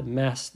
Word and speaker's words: Massachusetts: Massachusetts'te e, Massachusetts diye okunuyor bu arada sürekli insanlar bununla alakalı soruyorlar. Massachusetts söylenmesi zor bir eyalet. Ee, genelde zaten insanlar konuşurken günlük hayatta da mass diyorlar. Hiç, hiç kimse uzun şeklini söylemiyor ama İngilizce olarak telaffuzu Massachusetts.